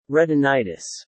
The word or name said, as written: retinitis